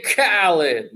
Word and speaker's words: Khaled: You mean DJ Khaled. – There you go. – A lot of people mispronounce it.